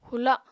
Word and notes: hula: apatani

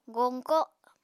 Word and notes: goñko: Apatani

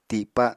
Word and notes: tipa: Apatani

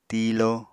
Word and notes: tiilo: Apatani

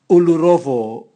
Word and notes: ulurovo: Raga

